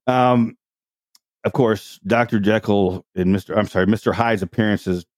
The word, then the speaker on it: Jekyll: The only version where Dr. Jekyll's name is pronounced correctly, Dr. Jekyll.